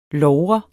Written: logre

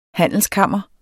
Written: HANDELSKAMRE

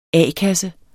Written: a-kasse

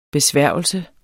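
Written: BESVÆRGELSER

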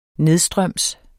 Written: nedstrøms

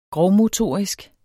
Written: grovmotorisk

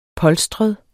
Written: polstret

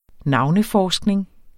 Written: NAVNEFORSKNING

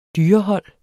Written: dyrehold

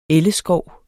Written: elleskov

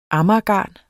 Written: AMAGERGARN

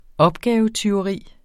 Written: opgavetyveri

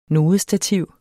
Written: NODESTATIV

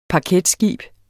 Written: paketskib

